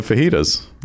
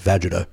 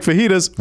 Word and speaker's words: fajitas: I was in my 30s until I realized that wasn't pronounced vagita.